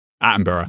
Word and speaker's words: Attenborough: You know, you got David Attenborough. Uh, how do you say his name? David Atten. Attenborough. Attenborough, yeah.